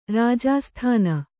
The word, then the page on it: ರಾಜಾಸ್ಥಾನ: kannada